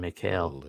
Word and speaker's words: Mikhail: Two guys named Michael that both pronounce it differently. Michelle and Mikhail.